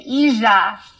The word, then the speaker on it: hija: So, and we pronounce our J. So instead of, for example, hija, which would be girl, we say hija.